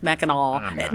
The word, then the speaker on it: Mackinac: Mackinac, spelled Mackinac, but pronounced Mackinac. That's sort of like you don't say Belzoni. Belzona. You don't say Mackinac.